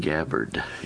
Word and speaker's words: Tulsi Gabbard: Is that how you say her name? Tulsi Gabbard? – Tulsi Gabbard, yeah.